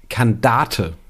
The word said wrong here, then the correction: Kandare: Ach so, du hast ein T am Ende eingeführt.